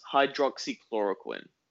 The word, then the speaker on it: hydroxychloroquine: It's hydroxychloroquine.